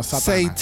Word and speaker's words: Satana: So es Satana, no Satana.